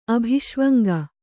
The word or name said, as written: ಅಭಿಷ್ವಂಗ